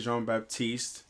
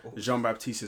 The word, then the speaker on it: Jean Baptiste: So, my name is Assange Jean Baptiste. Jean Baptiste is French. I want you to pronounce it right, okay? Right, because it sounds better when you pronounce it right, right? It's French.